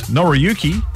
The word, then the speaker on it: Noriyuki: As seen in Happy Days and the Karate Kid movies, Noriyuki, did I say that right? – Mm-hmm.